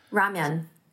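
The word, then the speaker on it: ramyeon: So it's ramen. The Americans pronounce it ramen, R-A-M-E-N, but Koreans pronounce it ramyun.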